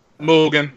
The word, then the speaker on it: Mugen: Because, like, the way you're describing it sounds like some shenanigans from Mugen. I don't know how to pronounce it. – Mugen. You got it right.